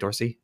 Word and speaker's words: Dorsey: So this is from uh, GM on uh, Don uh, John Dorsey. I'm saying that right, Dorsey? – Yeah, yeah, Dorsey. Okay.